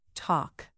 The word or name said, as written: talk